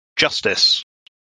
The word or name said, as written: Justice